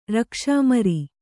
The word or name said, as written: ರಕ್ಷಾಮರಿ